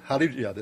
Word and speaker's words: Hallelujah: 曲目はローマ字で HALLELUJAH と書いてハレルヤです。